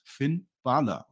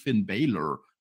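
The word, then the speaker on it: Finn Bálor: Finn Balor sagt Finn Balor. Und zwar genauso, wie ich das ausspreche. Er nennt sich selber Finn Balor. So heißt das. Und nicht Finn Baylor, wie der Herr Flütter immer sagt.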